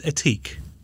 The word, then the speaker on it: Ethique: And it's Ethique. – It is, yeah. – Awesome. – Silent H.